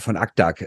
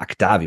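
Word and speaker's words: Akdağ: Und gerade von Aktak oder Akda, wie man ihn eigentlich aussprechen müsste, da erwarten sie sich hier schon was.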